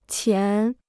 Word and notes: 前: qián

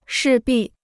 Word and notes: 势必: shì bì

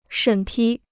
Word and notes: 审批: shěn pī

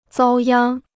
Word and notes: 遭殃: zāo yāng

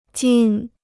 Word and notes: 金: jīn

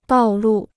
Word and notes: 道路: dào lù

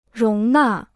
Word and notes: 容纳: róng nà

